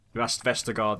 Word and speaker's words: Vestergaard: Is it Vendegaard, whatever his name is? I don't know how you pronounce it. Vestergaard, there we go.